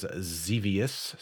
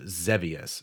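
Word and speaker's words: Xevious: Right now, at the time of this recording, it is Zevius. So, or I found out it's actually technically pronounced Zevius. So I've been saying that wrong.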